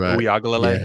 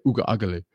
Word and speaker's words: Uiagalelei: DJ Uiagalelei. Yeah, DJ Uiagalelei.